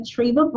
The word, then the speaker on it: Treva: So on LinkedIn, you can search for me, Treva Brown. I'm sure I'll pop up under the U.S. Naval Research Laboratory. On Instagram, Diva_Treva. I know because my name rhymes with Diva.